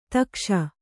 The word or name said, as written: ಥಕಾಸು